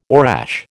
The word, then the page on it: orache: American English